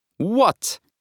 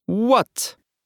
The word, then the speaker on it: What: Savelkiet lūpas tā, it kā vēlētos noskūpstīt jaukam cilvēkam vaigu. Tātad esam gatavi skūpstīt un izrunājam. What? What? What?